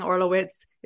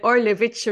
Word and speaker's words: Orlewicz: I have Polish people tell me all the time, stop saying Orlowitz. It's like Orlewicz or something.